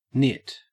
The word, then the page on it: nit: Australian English